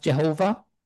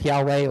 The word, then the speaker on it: Yahweh: Historically, it was in the 19th century, it was often pronounced Jehovah. We know that it wasn't ever pronounced Jehovah in Hebrew, but it's something like Yahweh or similar.